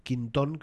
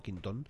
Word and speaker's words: Quinton: No sé si lo digo bien, René seguramente, Quinton, creo que se pronuncia con acento en la última O, Quinton, que tenía la teoría de que el entorno natural para las células vivas era el agua salada.